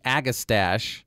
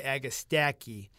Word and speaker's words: Agastache: Well, CH in Latin is a K. So when you see a CH together, you want to say K almost all the time. So that's why Agastache.